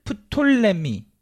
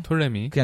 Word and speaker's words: 톨레미: 그래서, 이제 이집트는 프톨레미. 근데 이제 피 발음 안 해요. – 네. – 촌스럽게. – 톨레미. – 그냥 톨레미죠.